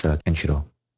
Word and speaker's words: Kenshiro: Oh, by the way, it's Kenshiro.